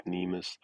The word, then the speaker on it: Nemus: It's definitely called Nemus.